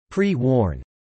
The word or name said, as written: pre-worn